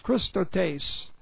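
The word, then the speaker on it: chrestotes: C-H-R-E-S-T-O-T, long E-S, Christotes.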